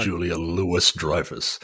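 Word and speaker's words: Louis-Dreyfus: Please stop pronouncing the S in her name. And yes, she, it is well deserved. – Oh, is it Julie Louis Dreyfus? – Yes. Oh, not sorry. Julia Louis Dreyfus.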